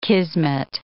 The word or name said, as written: kismet